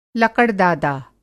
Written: लकड़-दादा

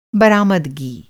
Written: बरामदगी

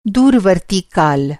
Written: दूरवर्ती

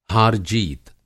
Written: हार-जीत

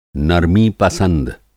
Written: नरमी-पसंद

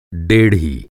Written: डेढ़ी

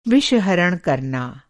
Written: विषहरण

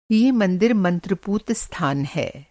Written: मंत्र-पूत

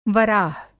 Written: वराह

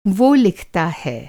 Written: वह

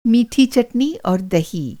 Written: चटनी

